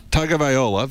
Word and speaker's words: Tagovailoa: Give me the pronunciation on, is it Tagovailoa? – Tagovailoa, the quarterback for Alabama.